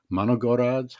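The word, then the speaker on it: Manogorods (моногород): And as you also point out in your foreign affairs article, and I'm going to butcher the pronunciation of this, but Manogorods, how do you pronounce that? – That's pretty good.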